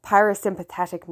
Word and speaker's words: parasympathetic: I actually had to stop the recording there and look up that word to make sure I pronounced it properly, and I did. Parasympathetic nervous system.